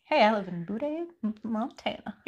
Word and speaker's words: Butte: And I think we've looked it up and it's actually Butte.